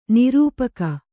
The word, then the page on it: ನಿರೂಪಕಿ: nirūpaki